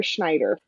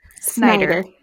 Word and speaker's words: Snyder: Is it Snyder or Schneider? – Snyder. – Snyder. Snyder. Okay. Because sometimes it's spelled like that, and it's Schneider.